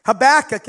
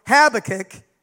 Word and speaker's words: Habakkuk: His name can be pronounced Habakkuk, as I prefer, or Habakkuk, as people somewhere else may pronounce his name.